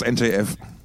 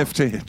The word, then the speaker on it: NFT: Das Dong, das NTF. – Nee, genau. NFT.